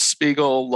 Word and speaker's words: Spiegel: It's my name, Charles Spiegel Law.